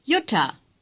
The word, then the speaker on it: Jutta: Jutta. – Say Jutta. – Again. – Jutta. – The letter J is pronounced like our Y in yes.